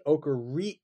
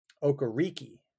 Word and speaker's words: Okereke: So his name used to be like the way he pronounced it for years. And there's an interesting story on the Colts website about this from 2021. His parents are from Nigeria, and for years it went Okereke.